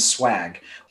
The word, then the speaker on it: schwag: To help support the club you love, think about picking up some GTM swag. And thanks to one of our members, we recently released a new online store. The URL is swag, which is spelled S-C-H-W-A-G.